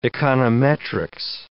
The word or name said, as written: econometrics